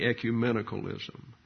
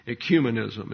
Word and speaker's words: ecumenism: And they would therefore found churches in that area, and that would be ecumenicalism. Ecumenism is the correct pronunciation.